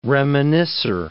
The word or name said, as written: reminiscer